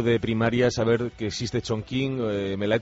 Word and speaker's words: Chongqing: Sí, porque es Chongqing, la Q se pronuncia como un H en mandarín. – Ah, Chongqing.